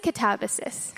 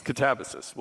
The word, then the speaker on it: Katabasis: So, and I'm going to ask you about Katabasis, but is it Katabasis? Katabasis? I hear everybody saying it however. – And they can say it however they like. – How do you say it? – I say Katabasis. – Katabasis. We'll go with that. So, in Katabasis, you set the main character's inner conflict early on.